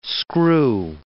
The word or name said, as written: screw